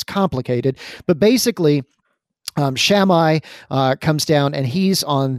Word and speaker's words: Shammai: There was Shammai and Hillel.